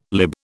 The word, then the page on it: lib: British English